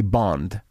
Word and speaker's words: Bond: Mais il faut que tu fasses attention à la façon que tu prononces son nom de famille. C'est Bond.